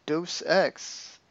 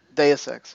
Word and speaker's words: Deus Ex: But all right, so for PlayStation Plus, pretty big game, Deus Ex, Mankind Divided. Deus Ex, sorry. I was saying this wrong.